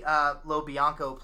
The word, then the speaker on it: LoBianco: In fact, there's Tony Lob. I'm going to mispronounce his name, so let me get it right. So Tony LoBianco plays the detective who's, you know, he's deeply religious.